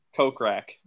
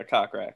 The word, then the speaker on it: Kokrak: Kokrak. – It's Kokrak, right? – Yeah, Kokrak.